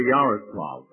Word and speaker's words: Yaroslavl: How did you come to go to Yaroslavl, if I said it right? – You just fairly well. I think he qualifies to come and visit us if he knows how to pronounce it.